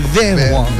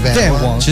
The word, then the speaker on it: 泛黄: 这我记忆印象非常深刻，因为泛黄这个不好发音，是 van 黄 van 黄。